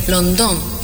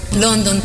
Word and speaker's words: Londón: And that dance, when it was adopted, the Paraguayan style became Londón, because in Guarani we accented every last syllable of every word. London became Londón, and Carapé, because everything that we adapted to the Paraguayan style becomes Carapé.